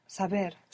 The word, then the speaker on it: saber: Saber. – Saber. This is a B. – It's a B, saber. – Saber. This is a B. So you're not going to pronounce a V.